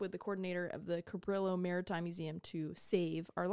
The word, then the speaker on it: Cabrillo: According to online pronunciation lady, it's Cabrillo. So we don't pronounce it. – Cabrillo. – Pronounce it like Spanish.